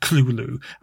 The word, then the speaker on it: Cthulhu: And, like, Yoshi insists that Cthulhu should be pronounced Cthulhu. And that is how it's pronounced in this film as well by Russ's dad, Cthulhu.